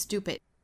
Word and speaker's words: stupid: 包括还有一点要注意的呢，就是 stupid 里面的这个字母 t，那这里面呢，前面是因为有了一个 s。后面的这个清辅音呢，我们不送气了，发一个类似于一个 d d 的发音，stupid，stupid，stupid。 It will spend its whole life，在 life 的后面也可以停顿一次，喘一口气。 Believing that it's stupid，会花上他一生的时间。